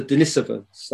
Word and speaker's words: Denisovans: Well, as you've already alluded to, there were a people there called the Denisovans. That is the correct pronunciation, by the way.